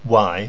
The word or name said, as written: wai